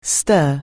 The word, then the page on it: stir: anglais